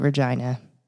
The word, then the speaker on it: Regina: It's called Regina. And everybody usually laughs if when they hear it for the first time. Yes, they call it the city that rhymes with fun.